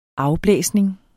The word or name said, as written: afblæsning